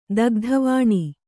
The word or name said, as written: ದಗ್ಧವಾಣಿ